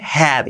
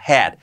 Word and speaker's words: had: Cuidado que no es had con la H, had. Y la A un poco ea, had.